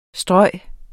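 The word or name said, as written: stryge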